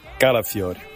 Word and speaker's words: Calafiori: Calafiori. – Calafiori.